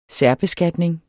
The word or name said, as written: særbeskatning